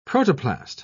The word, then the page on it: protoplast: British English